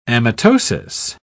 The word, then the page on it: amitosis: British English